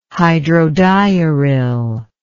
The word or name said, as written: hydrodiuril